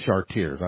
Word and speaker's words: Chartiers: Here, all we must do is replace Philippi with Cartier. Oh, that's Chartiers. I was pronouncing it in the French.